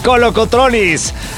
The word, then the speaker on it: Kolokotronis: Gusto saludarte, acompañarte aquí desde el estadio Teodoros Kolokotronis. ¿Estoy bien? – Sí, perfectamente pronunciado. – Kolokotronis.